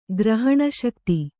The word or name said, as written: ಗ್ರಹಣಶಕ್ತಿ